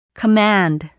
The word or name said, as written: command